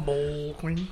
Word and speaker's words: Mulqueen: You have to really pronounce that L. – Mulqueen.